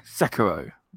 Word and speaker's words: Sekiro: Sekiro.